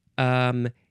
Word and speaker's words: etidronate: And this is called also etidronate disodium.